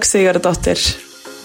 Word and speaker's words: Sigurðardóttir: So, but Ingibjörg Sigurðardóttir, that's how you say it.